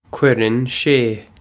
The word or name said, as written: cuireann sé